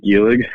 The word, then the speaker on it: Guelig: I'm Dylan Guelig.